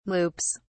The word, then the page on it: loops: British English